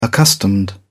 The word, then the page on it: accustomed: British English